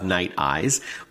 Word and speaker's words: Nite Ize: And so I wanted to invite onto the show Rick Case, the founder and CEO of Night Eyes, which is spelled N-I-T-E-I-Z-E.